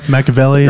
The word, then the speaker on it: Machiavelli: Machiavelli? – Right. – Machiavelli.